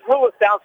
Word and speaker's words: Lewistown: There's a W, so it's town. – That's what I've always said. – Yep. – Nice. Well, that settles it, folks. It's Lewistown, so I have been pronouncing it correctly.